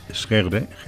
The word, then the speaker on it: Sjögren: Entonces creo que se pronuncia Sjögren. – Sí. – Y en por la diéresis que tiene.